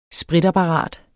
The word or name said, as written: spritapparat